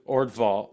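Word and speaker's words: Øvredal: In this horror story, much closer in the spirit to Stephen King's novel than to cheap horror films, Orvalde, the director's name, I don't know how to pronounce it. – Andre Orvdal.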